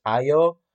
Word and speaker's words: Ayo: So I do like that part, and the fact that he's very casual with, I think her pronunciation is Ayo.